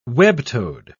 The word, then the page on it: web-toed: British English